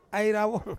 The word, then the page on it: ai rawong: Ansus